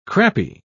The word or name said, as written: crappie